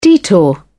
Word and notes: detour: British English

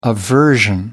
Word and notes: aversion: American English

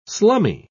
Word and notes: slummy: British English

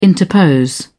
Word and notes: interpose: British English

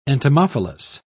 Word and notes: entomophilous: British English

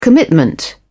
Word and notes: commitment: British English

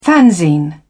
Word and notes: fanzine: British English

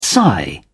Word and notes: sigh: British English

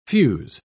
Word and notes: fuze: British English

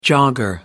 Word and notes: jogger: American English

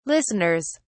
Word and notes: listeners: British English

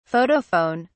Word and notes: photophone: British English

